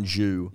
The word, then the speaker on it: jus: Red wine jus. – J-U-S. – I think there's W. – I think it's just one U. – I think it's one U, but I also think the S is silent.